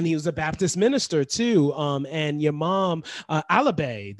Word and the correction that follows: Alave: did I pronounce that right? Alabe? – Alavi. – Alavi. Oh, Alavi. Oh, Alavi. – Yes, Alavi. – A-L-A-V-E.